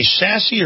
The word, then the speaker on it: Sasse: Do you say sassy or do you say sassy? It's sass.